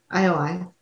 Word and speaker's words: IOI: IOI.